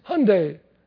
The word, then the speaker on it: Hyundai: And, you know, everybody's saying, and the end of the commercial is so neat, they say, and it's Hyundai. They're learning to pronounce it now, just like Sunday.